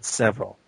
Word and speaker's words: Sevryll: And mine is @Sevryll, S-E-V-R-Y-L-L. – Or as you say when you can't pronounce it, Sevryll.